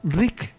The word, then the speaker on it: Ryke: Y busquen historia de la biodescodificación y busquen la historia del doctor Rick, se escribe R-Y-K-E, Hammer.